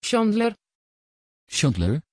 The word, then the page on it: chandler: Dutch